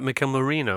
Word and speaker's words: Mikel Merino: I just want to ask Rafa as well about Mikel Merino.